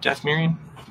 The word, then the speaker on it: Dathomirian: So to go up against a Dathomirian, is that right? Dathomirian? Am I pronouncing that right? – Yeah.